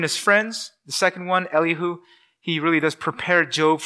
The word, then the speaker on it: Elihu: The second one will be kind of Mr. Fifth Wheel, Elihu, or if from the southern states, we can call him Elihu. I've heard that, but I'm just going to call him Elihu. You just pronounce it any way you want.